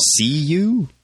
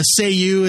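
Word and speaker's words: Cei-U: See you? – Say you is how it was always pronounced.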